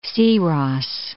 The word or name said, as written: Syros